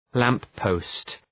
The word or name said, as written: lamp-post